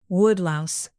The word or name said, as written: woodlouse